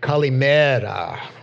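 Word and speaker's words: Kalimera (Καλημέρα): So I'm going to begin with greeting you with Kalimata. That is, yeah, I think I pronounced it wrong. Thank you. We actually must have a Greek-speaking person there because it's supposed to mean good morning in Greek. Kalimera, right?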